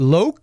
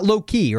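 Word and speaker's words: Loek: Is it Loke? Because it's not Loki, right? That'd be like L-O-K-E. It's L-O-E-K. – Yeah, it's D-I-K-K-E-R. Is it Dicker? – Yeah, Loke Dicker.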